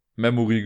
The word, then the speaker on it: Memory: es heißt Memori. Nein, Memory natürlich.